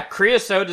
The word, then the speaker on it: Creosote: Yeah, Creosote is how you say it.